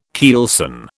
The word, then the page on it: keelson: British English